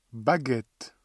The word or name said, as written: baguettes